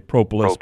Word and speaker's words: propolis: I would call it propolis is actually how it's supposed to be pronounced. Around here we say propolis.